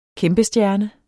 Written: kæmpestjerne

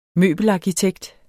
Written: møbelarkitekt

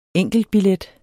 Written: enkeltbillet

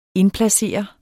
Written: indplacere

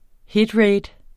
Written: Hitrate

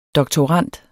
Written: DOKTORANDERNE

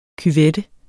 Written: cuvette

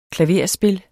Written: klaverspil

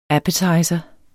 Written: appetizer

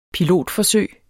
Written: pilotforsøg

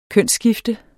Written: kønsskifte

